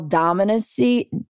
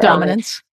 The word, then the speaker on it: dominance: And it's this display of male dominancy, if I'm saying that right. Dominance.